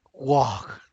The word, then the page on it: wag: Santiago Matatlán Zapotec